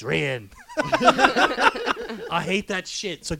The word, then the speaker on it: Rian: And by the way, it's not Ryan. It's Rian.